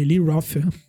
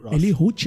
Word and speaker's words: Roth: Eli Roth. Eli Ruth, acho que fala Ruth, né? não Ruth, não.